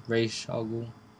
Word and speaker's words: Ra's al Ghul: And they just pronounce it Ra's? – Yeah. – S-H? – Yeah. Sh. Mm. Ra's al Ghul.